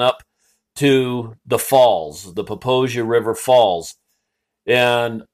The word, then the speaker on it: Popo Agie: Now, I know I said Poposia. It's a Crow word that means grass river, but it is spelled P-O-P-O-A-G-I-E. So tourists usually call it the Popo Aggie River, to which the locals all know, hey, you're from out of town. It is actually pronounced Poposia, and it comes down out of the Wind Rivers, and it's a fairly fast-moving river.